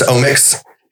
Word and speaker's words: OMX: And it's spelled O-M-X. – Yeah, it's spelled O-M-X, so it's omics, just kind of like a play on the word, right? O-M-I-C-S.